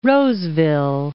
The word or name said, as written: Roseville